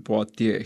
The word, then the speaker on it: Poitiers: Prantsuse keel on meie jaoks võibolla natukene keeruline keel, aga noh, nii palju oskame hääldada küll Poitier, Hilarius.